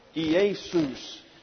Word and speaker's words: Iesus: So we have J-E-S-U-S. But Iesus is the way it's pronounced.